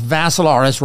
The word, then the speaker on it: Vassilaris: And it's Dimitri with three I's, D-I-M-I-T-R-I. Last name is Vassilaris, rhymes with Assilaris, except you had a V.